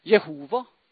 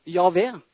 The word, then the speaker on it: Jehova: Noen uttaler det Jehova, andre Javé.